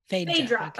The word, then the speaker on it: Phaedra: In the audiobook, they said Phaedra. – Phaedra. Okay. Thank you. – Thank you, audiobook. So I thought like for the conversation with Phaedra,